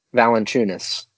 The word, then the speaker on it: Valančiūnas: Valančiūnas.